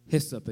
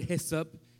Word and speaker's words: Hyssop: Hyssop. If you have any problems pronouncing the word hyssop, think of these three words. Hey, what's up? And then say it really fast.